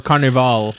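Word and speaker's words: Carnivàle: Carnival on HBO, or Carnival, as it's pronounced.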